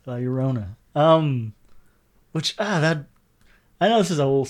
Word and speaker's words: La Llorona: Yeah, La Llorona.